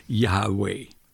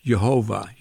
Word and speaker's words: Yahweh: Scholars usually say that the name of God is to be pronounced Yahweh. That's a supposition. It's not sure. But I think it's probably correct. Others pronounce that name as Jehovah, you know, from the religion called Jehovah's Witnesses. That probably is incorrect.